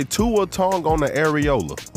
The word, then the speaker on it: Tua Tagovailoa: What did you just call that man? – Tua Tagovailoa. I don't know how to say his last name. – Did you just say Tua Tagovailoa? – Yeah, bro. – That's what he said. – Yes, bro. That's what his name sound like.